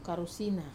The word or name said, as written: karusina